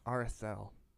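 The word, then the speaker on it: Aredhel: So about 200 years after Gondolin was created, Turgon's sister, Aradrel. Is that how you say it? – Arathel. – You know, it's tough because there's no TH.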